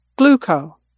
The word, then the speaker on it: Glooko: Gluco. That's spelled G-L-O-O-K-O.